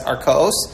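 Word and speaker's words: Arkaos (ערכאות): That's Arkaos.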